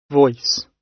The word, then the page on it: voice: British English